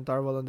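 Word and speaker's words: Tar Valon: It's interesting that they are pronouncing it Tarvalon. But in the audiobooks, it's Tarvalan. – Yeah, I know. So right. Most of the groups find themselves in Tarvalan this episode, except for Egwene and Perrin.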